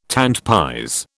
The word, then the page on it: tant pis: British English